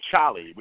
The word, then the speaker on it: Cholly: he gave me the name Cholly, which is spelled C-H-O-L-L-Y.